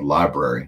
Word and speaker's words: LBRY: also one called Library. It's spelled LBRY.TV.